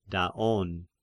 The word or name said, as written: Daon